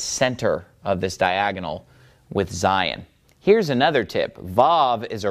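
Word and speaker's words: Zayin: The pronunciation of Zion is, obviously the letter makes a Z sound, Zion. But the word Zion, it's pronounced pretty similar to the place in the Bible, Zion.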